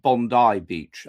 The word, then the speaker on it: Bondi Beach: I'm being told that it's pronounced Bondi Beach.